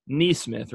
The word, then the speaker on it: NeeSmith: This is Blake NeeSmith, right? – Yeah, that's right.